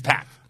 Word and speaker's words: Pac: Whatever they said on TV, because Jim Ross said that he specifically asked him okay. – how is it pronounced, so he would say it right on TV, and the way he says it on TV is how packed told him it is.